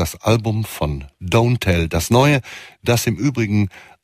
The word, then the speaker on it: DNTEL: Hier geht's etwas schneller weiter mit einem Titel aus dem neuen Album von Jimmy Tamborello, besser bekannt als DNTEL, und da wird er Don't Tell ausgesprochen, einstmals eine Hälfte dieser großartigen Elektroband The Postal Service mit dem Death Cab for Cutie Sänger Ben Gibbard an seiner Seite.